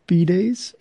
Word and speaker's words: fides: Kelly, and my Scotch bona fides, is it fide or fides? I'm never sure. – Neither am I, so we're going to go with my pronunciation.